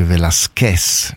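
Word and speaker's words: Velásquez: I do speak just a little Spanish, and I know that the correct pronunciation is Jackie Velasquez, not Velasquez.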